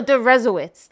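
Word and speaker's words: Deresiewicz: It's actually Derowitz. No, Derezowitz. That's how it's pronounced.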